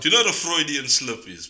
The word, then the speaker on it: Freudian: Yeah, and then Daniel Caesar's, I think you pronounce it Freudian or Fruidian. – Freudian. – Freudian. There we go. – Is it F-R-E-U-D? – Yeah.